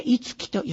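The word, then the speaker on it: 一樹: いつき、漢数字の一の字でいつ、樹木の樹という字で木で、いつきと読みます。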